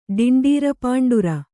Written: ಡಿಂಡೀರಪಾಂಡುರ

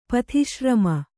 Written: ಪಥಿಶ್ರಮ

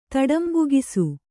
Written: ತಡಂಬುಗಿಸು

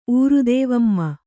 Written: ಊರುದೇವಮ್ಮ

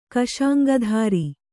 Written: ಕಶಾಂಗಧಾರಿ

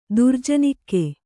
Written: ದುರ್ಜನಿಕ್ಕೆ

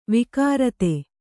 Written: ವಕ್ರಿತ

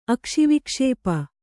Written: ಅಕ್ಷಿವಿಕ್ಷೇಪ

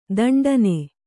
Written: ದಿಂಟೆನಾ

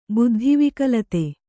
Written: ಬುದ್ಧಿವಿಕಲತೆ

